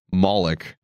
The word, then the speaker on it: Moloch: Now, I think we should just start with the most interesting element, Moloch.